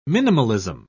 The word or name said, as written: minimalism